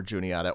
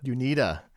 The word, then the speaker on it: Juniata: Mass Dartmouth over Worcester, Husson over Anna Maria, Shenandoah over Juniata, or if you prefer, Worcester, I believe. It was Huson over Anna Maria and Shenandoah over, was it Juanita? – Junita. – Junita, a new mispronunciation of Juniata.